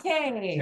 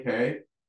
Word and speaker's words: Cay: Castaway Cay, not K. K, I'll probably say K. They spell it C-A-Y and it always throws me off, but it's Cay.